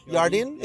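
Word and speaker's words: jardín: Is the H J? The J is H. The J is pronounced H. Jardin?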